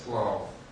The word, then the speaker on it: plough: По-английски пишется именно так. P-L-O-U-G-H. Плуг пишется, только читается плау.